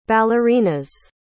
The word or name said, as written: ballerina's